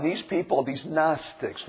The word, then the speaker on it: Gnostics: Gnostics. Gnostics. And it's spelled with the letter G. This is number 1. G-N-O-S-T-I-C-S. Gnostics.